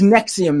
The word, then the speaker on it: NXIVM: NXIVM. It's a weird spelling. – Yeah. – NXIVM. Yes. It's pronounced Nexium, though.